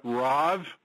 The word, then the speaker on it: Rav: Good morning, and it's Rav.